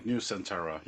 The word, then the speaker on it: Nusantara: Nusantara, yeah.